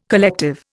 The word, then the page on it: collective: Indian